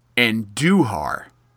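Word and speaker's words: Andújar: Uh, it's pronounced Anduhar. – Anduhar. – Yes, you put the emphasis on the do, um, which I don't know why.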